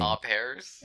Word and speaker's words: au pairs: What do you pronounce it? Arupars or Arupars or, you know, they're trying to get here. That's the thing. – Au pairs.